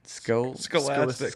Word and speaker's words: Scholastic: Scholistic. Well, how do you say it? – Scholastic. – Scholastic. – Jeez. Yeah, you got to do the whole thing.